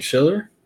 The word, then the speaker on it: Schiller: I'm here with Brian Schiller.